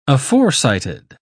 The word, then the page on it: aforecited: British English